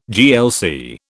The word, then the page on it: GLC: British English